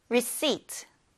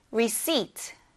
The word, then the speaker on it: receipt: P가 들어가 있는데 P는 발음하지 않아요. 그래서 receipt가 아니라 P를 뺀 receipt라고 발음해주면 됩니다. 따라해보세요. receipt, receipt, receipt.